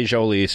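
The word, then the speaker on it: Jhoulys: Unless I hear it from him himself, from Jolys Chacín himself, that I'm saying it wrong, I'm sticking with Jolys. – Okay. – Soft J, Jolys Chacín.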